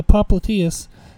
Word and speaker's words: popliteus: I don't even know if I'm saying that right. It's popliteus. So it's the muscle on the backside of the knee.